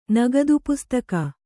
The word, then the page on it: ನಗದಿಪುಸ್ತಕ: Kannada